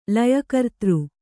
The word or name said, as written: ಲಯಕರ್ತೃ